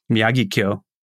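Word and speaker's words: Miyagikyo: How do you pronounce that? – Miyagi-kyo? – Miyagi-kyo. That's it. Yes. Miyagi-kyo.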